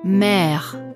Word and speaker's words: mère: Das Strichlein auf dem E, das nach hinten zeigt, heißt Accent grave und bedeutet, dass das E wie ein Ä ausgesprochen wird, wie in Märchen. – Mère, Père, Frère, Problème, Trait.